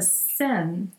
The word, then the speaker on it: Seine: the Seine.